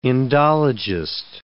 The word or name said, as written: Indologist